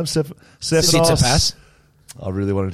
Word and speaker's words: Tsitsipas: Tsitsipas is coming. – Wow. – Dan has said Tikasaska pass. That's how he's gone with it. Tikatek pass. Wow. – Stefanos Tsitsipas will be in town, the number 3 in the world, alongside Mario Sakari, number 6.